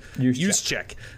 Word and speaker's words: Juszczyk: I think creative offensive coordinators have found ways to use guys like Kyle Juszczyk, or Juszczyk, how do you say his name, from San Francisco? – Juszczyk.